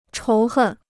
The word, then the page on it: 仇恨: chóu hèn